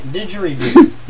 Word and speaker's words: didgeridoo: Buddhist monks used to chant Aborigines, I almost said that wrong, use the, I can't even pronounce it, Diridoo, is that it? Didgeridoo.